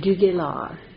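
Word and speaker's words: de Galard: I pronounce it Duguelard. It's a French name.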